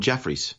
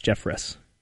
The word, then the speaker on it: Jeffress: Is it Jeffress? Is that how you say his name? Because I've been calling him Jeffries. – No, it's Jeffress. Jeffress.